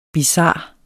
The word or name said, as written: bizar